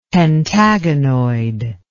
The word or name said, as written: pentagonoid